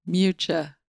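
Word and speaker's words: Miuccia: M-I-U-C-C-I-A. I say Miyuka. I don't know if that's right. – Miyuka. K- Can you show the quick YouTube? – It's been a while since we did one of these. Oh, Mucha. – Mucha? – Mucha. Ch, like C-H-A. Mucha Prada. – Well, you learned something new today as well. – T-I-L.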